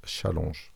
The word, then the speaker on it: challenge: Ah non, c'est bien challenge parce que c'est un mot français, du, originaire du vieux français challenge. Donc ça se prononce challenge.